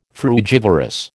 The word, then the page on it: frugivorous: American English